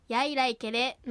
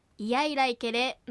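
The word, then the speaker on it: イヤイライケレ: 早く発音するといが弱まってやいらいけれのように発音されることもありますが、ゆっくり丁寧な発音ではいやいらいけれのようにいがはっきり発音されるようです。初歩のうちはいを意識して発音した方が良いでしょう。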